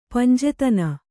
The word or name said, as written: ಪಂಜೆತನ